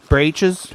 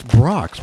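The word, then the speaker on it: Brach's: It's not braches. – I don't know how to say it. – It's Brock's, Michael.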